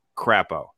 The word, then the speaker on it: Crapo: And so, yeah, Idaho and Mike Crapo in the Senate, perfect name, perfect name from a guy from Idaho because his ideas are going to be crap, but also rhyme with the name of the state, Crapo.